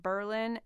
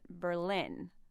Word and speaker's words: Berlin: Or why is Berlin, New Hampshire, pronounced Berlin and not Berlin? – Yeah, like I've got so many questions myself.